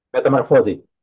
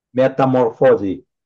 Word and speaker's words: Metamorfosi: Metamorphosi. – Metamorphosi. – Yeah, it's more like in an Italian way of pronouncing it, although we are not Italian. We just like Italian names.